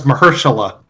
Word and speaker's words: Mahershala: Marsha Shala, right? – No, it's Mahershala. – Mahershala Ali. – All right, I'll go with that. – You just call him M. Ali. – Mr. Ali. So Mahershala? – Mahershala.